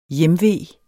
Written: hjemve

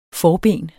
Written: forben

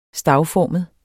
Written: stavformet

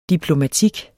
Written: diplomatik

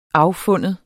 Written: affinde

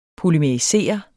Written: polymerisere

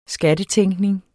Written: skattetænkning

